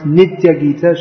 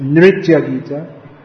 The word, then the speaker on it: Nritya Gita: Sometimes that's pronounced Nitya Gita. Srila Prabhupada himself often pronounced it like that because that's the Bengali way of pronouncing it. But it's Nritya Gita.